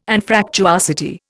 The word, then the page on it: Anfractuosity: Indian